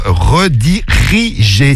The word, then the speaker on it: redirigé: Il oublie une syllabe. Redirigé. T'as oublié redigé. Non, c'est redirigé sur Snap.